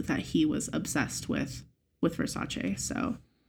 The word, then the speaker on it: Versace: I like the way you say Versace. – That's how it's pronounced.